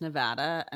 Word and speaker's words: Nevada: It's Nevada. And if you say Nevada, you're a fucking idiot. And say it right. – All right. Well, fuck you too. All right then. – It's definitely, I think, a like East Coast versus West Coast. Like people on the West Coast know it's Nevada. People on the East Coast say Nevada.